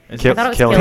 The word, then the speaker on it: Cillian: Cillian.